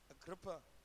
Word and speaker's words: Agrippa: And he said to Agrippus. Say, pronounce it for me. Agrippa. Your version says Agrippa.